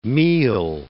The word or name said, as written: meal